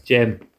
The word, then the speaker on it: Cem: Jem. I think it's like built with like a CEM, but pronounced Gem.